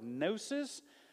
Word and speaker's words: gnosis: Gnosticism comes from the Greek word gnosis, which is spelled with a silent G like our word gnat, and it means knowledge.